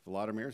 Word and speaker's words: Volodymyr: Vladimir is how you say it in Ukrainian.